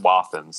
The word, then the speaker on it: Wathen: Yeah, I've met a guy who's an artist, and he's like a – you know, third generation non-distiller, and he pronounces it Wathen. So I go with Wathen.